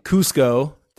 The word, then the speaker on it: Cusco: You have to explain where this is, but 5 1-mile repeats in a row in Cusco.